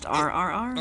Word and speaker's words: RRR: It is from a film called, is it just called RRR, or do you pronounce it a different way? Yep. – Okay. – It's just RRR.